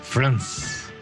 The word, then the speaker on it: France: Es como decir Francia, pero sin abrir la boca. France.